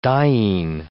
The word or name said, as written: diene